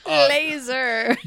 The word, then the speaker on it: Lazar: Also, his last name is spelled L-A-Z-A-R. So I could call him Bob Lazar if you want me to. – Laser.